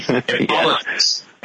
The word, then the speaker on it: Hippolytus: Hippolytus. And that, by the way, Bob checks, that's the right pronunciation.